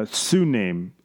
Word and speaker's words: TsuNAME: This flaw is called a Suname. It's spelled T-S-U-N-A-M-E.